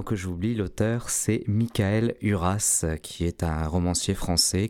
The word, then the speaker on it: Uras: Alors, Huras, comme ça se prononce, oui. – U-R-A-S. – U-R-A-S.